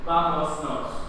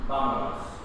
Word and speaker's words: vámonos: Pero no decimos vamos nos. ¿Qué decimos? – Vamos. – Borramos esta palabra y es vámonos.